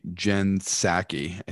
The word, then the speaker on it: Psaki: Now, White House Press Secretary Jen Psaki, I think that's how you pronounce her name, even though it's a P at the beginning. The P is silent.